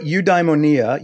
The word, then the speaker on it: eudaimonia: Uh, yeah, and I, I, learned it in Germany, so oi oi was the the pronunciation there. But eudaimonia, eu in in Greek is good, and daimon is a spirit.